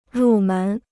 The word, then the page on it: 入门: rù mén